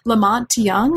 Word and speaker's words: La Monte Young: La Monte Young, is that how you pronounce his name? – Yes, yes.